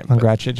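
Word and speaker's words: Pongračić: Pongracic, you're good. – You said it so great with the accent and everything earlier.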